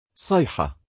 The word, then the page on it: syha: arabic